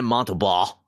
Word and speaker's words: Montembeau: It's going to be Sam Montembeau.